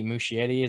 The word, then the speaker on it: Muschietti: But the director, Andy Muschietti, is that how you say his name? – Yeah.